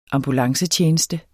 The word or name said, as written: ambulancetjeneste